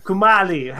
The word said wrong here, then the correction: Kumail: How do you say his name? – Kumail. – Kumail? – Yeah. – Kumail. – Like you. That is Kumail.